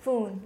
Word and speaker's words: Phuong: So I would say you would spell it like typhoon. So Phuong. – Oh, Phuong? – Phuong, like typhoon.